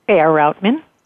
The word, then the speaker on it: Faier-Routman: Faier-Routman.